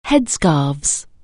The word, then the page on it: headscarf: anglais